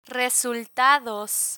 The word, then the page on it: resultados: spanish